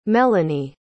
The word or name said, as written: melani